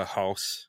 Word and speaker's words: Haas: Is it Haas or House? – I think it is the former, like a Haas.